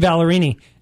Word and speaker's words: Ballerini: All right, covering Kelsey Ballerini.